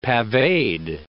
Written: paveed

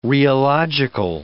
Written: rheological

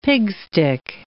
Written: pigstick

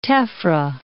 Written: tephra

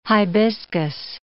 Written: hibiscus

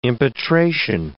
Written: impetration

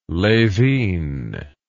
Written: lhevinne

